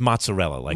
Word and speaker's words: mozzarella: I was like, what's up with mozzarella? She goes, no, that's not how you say it in Italy. It's mozzarella, everyone thinks.